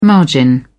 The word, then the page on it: margin: British English